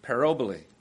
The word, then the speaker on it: parabole: This word parable comes from the Greek word that sounds a lot like it, if I say it properly, parabole. Parabole.